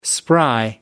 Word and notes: spry: American English

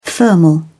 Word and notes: thermal: British English

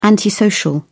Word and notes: antisocial: British English

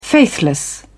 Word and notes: faithless: British English

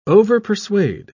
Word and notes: overpersuade: British English

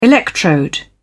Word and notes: electrode: British English

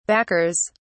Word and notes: backers: British English

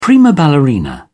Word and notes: prima ballerina: British English

